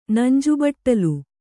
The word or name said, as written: ನಂಜುಬಟ್ಟಲು